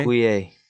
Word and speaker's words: Okoye: Okoye? It's spelled O-K-O-Y-E. Okoye? – Okoye.